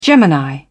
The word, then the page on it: gemini: British English